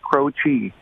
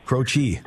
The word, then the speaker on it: Croce: It is pronounced Croce. – Croce. – So, yeah, Jim Croce. And it's Italian, so the C makes a ch sound.